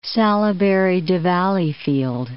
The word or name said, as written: Salaberry-de-Valleyfield